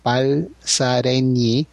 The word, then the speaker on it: Balsareny: Dice que la NY en catalán se pronuncia ñ, así que Balsareny.